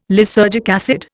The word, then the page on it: lysergic acid: Indian